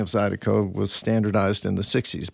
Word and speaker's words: Zydeco: Yeah, and then just the pronunciation, saying it quickly, it sounded more like a D than an R, so it went from Zydeco to Zydeco.